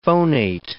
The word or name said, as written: phonate